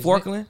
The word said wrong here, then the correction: Furkan: His name is Furkan.